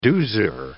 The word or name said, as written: doozer